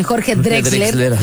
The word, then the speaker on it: Jorge Drexler: Tiene colaboraciones bien padres con Jorge Drexler. Nunca sé decir bien, Jorge Drexler, ya lo dije bien.